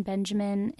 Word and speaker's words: Benjamin: And so Krug is spelled K-R-U-G, and Benjamin is like the boy's name.